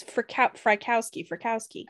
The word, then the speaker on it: Frykowski: Wojciechowski. – It's Frykowski. – Oh. – But his name is like Woodchick. And his girlfriend, Abigail Folger, were both stabbed to death while trying to escape.